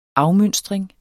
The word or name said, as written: afmønstring